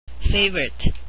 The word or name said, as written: favorite